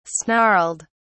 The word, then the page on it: snarled: British English